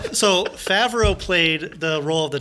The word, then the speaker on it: Favreau: He says John Favre every time. But it's Favreau.